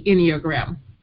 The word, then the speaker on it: Enneagram: What is the Enneagram? – All right. Well, first the pronunciation, it's Ennea, which means 9, and gram, which means a logo, a picture, or so it's a 9 picture, if I can put it like that.